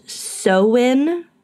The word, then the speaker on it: Samhain: Folk celebrated Samhain, which is actually pronounced Samhain, but like the languages.